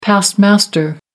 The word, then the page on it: past master: American English